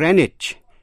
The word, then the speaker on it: Greenwich: Chữ Greenwich có hai cách đọc. Trước hết, âm E có thể đọc là I, âm W câm, và CH có thể đọc là CH hay là J. Vậy có thể đọc là Greenwich, Greenwich.